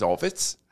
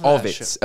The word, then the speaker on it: Ovits: My name is Ovitz. – Oh, Office. I love Ovitz. O-V-I-T-S.